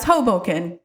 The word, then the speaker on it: Hoboken: Am I saying that right? – Yeah, it's Hoboken.